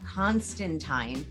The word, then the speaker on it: Constantine: When we talk about Constantine, okay, which is actually how it's pronounced, they made an entire movie called Constantine, based off of Constantine, and made it Keanu Reeves instead of some blonde.